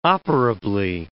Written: operably

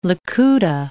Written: likuta

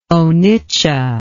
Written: onitsha

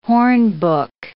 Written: hornbook

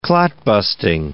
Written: clot-busting

